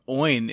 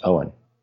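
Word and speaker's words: Eoin: I think I looked it up once and it's pronounced Owen.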